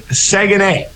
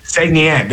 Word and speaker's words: Saguenay: I said it wrong there, which is kind of funny. It's Saguenay. The Chicoutimi Saguenay.